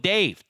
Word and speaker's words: Dave: That's code Dave, D-A-V-E only. Wait, not D-A-V-E.